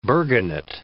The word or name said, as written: burgonet